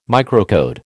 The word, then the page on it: microcode: American English